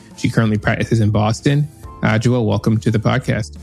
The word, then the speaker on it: Adjoa: Did I say that correctly? – Almost. – Oh, well, Adjoa, let's get it right. – Anyane-Yeboa. You just have to Adjoa, say it more perfect.